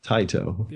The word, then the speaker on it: Taito: Um, and I didn't know it was a Qix until I started talking to this guy uh, he's kind of like this Tato or Taito. I'm not sure how you, I've never been sure how to pronounce Taito. that. Taito. Yeah.